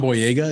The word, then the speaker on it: Boyega: John Boyega, is that how you say his name? – Yep.